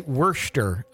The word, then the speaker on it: Worcester: They lived in it's so hard to say this, Worcestershire, Massachusetts. – I think that's wrong. Worcester. – Worcester? – I think it's Worcester. I don't know. You've got. – I believe the locals say Worcester.